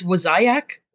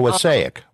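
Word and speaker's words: Wassaic: I always pronounce the name of the town wrong. It's Wasiac.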